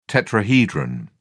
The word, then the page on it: tetrahedron: British English